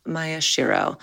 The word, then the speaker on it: Maeshiro: It's actually pronounced Maya Shiro. – Oh, really? Oh my gosh. – No apologies. – Worry. No, Mike, you're pretty well, actually. – Maya Shiro.